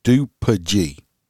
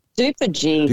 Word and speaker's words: DuPage: Aaron says it's said on DuPage, is how it's said. – DuPage.